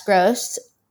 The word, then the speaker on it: Grose: You can find me at Jess Grose, J E S S G R O S E.